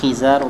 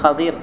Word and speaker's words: Khadir: Allah Ta'ala parle également du récit de Hadith Moussa alayhi salatu wassalam et d'un personnage qu'on appelle Al-Khadir. On appelle également Khizar ou bien Khadir, il y a différentes manières de le prononcer, cela.